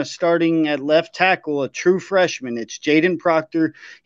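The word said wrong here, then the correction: Kadyn Proctor: He, if you look at allowed pressure, Caden Proctor. – Caden Proctor. Sorry, I mispronounced his name, but he's ended up allowing 33% of the allowed pressures last week against Middle Tennessee State.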